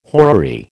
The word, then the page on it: horary: American English